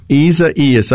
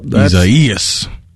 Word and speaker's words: Isaias: Isaias. – That's right. It's got an extra syllable in there. Gee whiz. On the ceasefire text line. – The emphasis is on the second E sound.